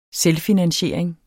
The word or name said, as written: selvfinansiering